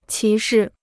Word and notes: 歧视: qí shì

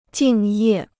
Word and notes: 敬业: jìng yè

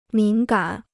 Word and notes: 敏感: mǐn gǎn

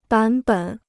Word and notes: 版本: bǎn běn

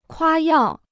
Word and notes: 夸耀: kuā yào

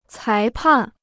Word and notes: 裁判: cái pàn

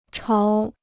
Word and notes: 潮: cháo

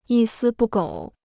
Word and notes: 一丝不苟: yī sī bù gǒu

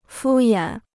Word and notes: 敷衍: fū yǎn

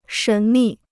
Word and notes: 神秘: shén mì

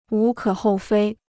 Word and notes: 无可厚非: wú kě hòu fēi

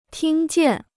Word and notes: 听见: tīng jiàn

